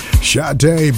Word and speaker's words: Sade: Sade by request. Her name spelled S-A-D-E, pronounced Sade. So sweet, but it's nice.